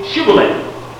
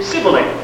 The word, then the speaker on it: Shibboleth: When he said, "No," then they said to him, "Then say Shibboleth," which is a strange thing. And it says here, and he said Sibboleth, poor old thick tongue thing, he couldn't say it. For he could not pronounce it.